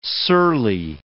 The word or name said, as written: surly